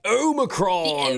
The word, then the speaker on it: Omicron: For one, with this Omicron, is that how you say it? – Omicron.